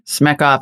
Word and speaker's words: Smechov: Smechov.